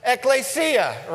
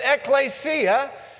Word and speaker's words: ekklesia: Ekklesia, right? You can't read it, but you can pronounce it, ekklesia.